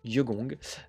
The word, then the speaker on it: Jegong: Euh, je vais vous parler maintenant de musique ambiante euh, avec le groupe Yegong qui est composé, donc ça s'écrit J-E-G-O-N-G, Yegong,